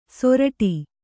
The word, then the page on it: ಸೊರಟಿ: soraṭi